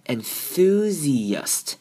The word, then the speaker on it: enthusiast: 接下来是 IA 的母音的组合跟它一般的念法不太一样，这边的 I 要念成 E 的声音 enthusi, 然后 A 呢念成 U 的声音 enthusiast。